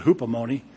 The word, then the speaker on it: hupomone: I call it hupomone. Rhymes with alimony, but that's not Greek at all. I mean, it's the spelling is the same, but the pronunciation is quite different.